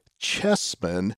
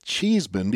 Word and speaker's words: Chessman: Chessman, but we had been pronouncing it Cheeseman because that's the way it seems to read. But we had a very kind listener inform me that, in fact, his name is pronounced Chessman, not Cheeseman, so we would like to make that correction.